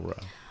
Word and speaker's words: paura: It's para or fear in Italian. – Oh, paura.